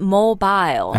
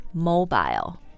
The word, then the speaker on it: mobile: I was being careful because in England they say mobile. – 因为这个词的发音呢，英式和美式的英语不一样啊。英式英语刚才说的是 mobile. – Yes, but here in Canada and in the USA, mobile, that is the pronunciation we use.